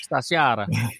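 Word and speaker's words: stagiaire: I første omgang, så bliver han øh, det, der hedder øh, star, stagiater, eh, øh, hvordan udtaler man det, sådan helt, sta, sta, sta, på italiensk, stagiare. Stagiare. – Stagiare. Stagiare. – Stas, bare uden i? E, stagiare. Okay, stagiare.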